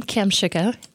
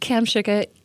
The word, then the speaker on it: Kamshuka: My name, Cam Shuka. If you Googled Cam Shuka, easily found. Twitter, Facebook, Instagram, all under Cam Shuka.